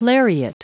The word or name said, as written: lariat